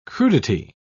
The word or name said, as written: crudity